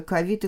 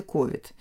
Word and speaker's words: ковид: Вот я хотела потом предложить, ну, правда, не очень приятная сама сущность, которая так обозначена, но порассуждать о новом слове ковид и ковид. Вот ковид, англоязычный вариант ковид, угу. да, а мы стали все-таки произносить ковид. Сейчас пока еще нет отражения угу. в словарях, как это правильно. И в речь встречается то и другое.